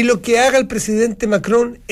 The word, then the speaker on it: Macron: ¿Cómo pronuncia Macron? Se nota que estudió muchos años y habla francés, porque lo pronuncia como los franceses, él. – Macron, hace una cosa.